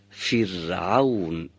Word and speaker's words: فرعون: و اگر قبل از اون، یعنی قبل از را ساکن، کسره باشه، باید را سکوندار رو نازک تلاوت کنیم و تلفظ کنیم. فرعون.